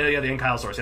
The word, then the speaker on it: ankylosaurus: He had like ridges, like an ankylosaurus type of tail. – Yeah. – Or is that the wrong? You know the one that's kind of like a ball. – Right. Yeah. The ankylosaurus.